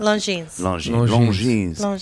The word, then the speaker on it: Longines: Yeah, how do you say that? – Longines. – It's French. – Again, that's what I said. – It is French.